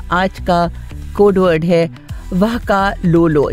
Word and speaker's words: vakalolo: वाकालोलो.